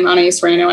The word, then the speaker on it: Anaïs Reynaud: I do have an Instagram, anaïsreynaudjazz, and I have a Facebook, which is just my name, Anaïs Reynaud. I can spell that, A-N-A-I-S-R-E-N-O.